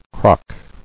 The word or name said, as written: Crocking